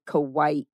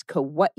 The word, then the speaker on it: Kauai: And it's improper to say Kauai, but it's Kauai is the correct pronunciation.